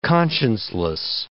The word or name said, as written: conscienceless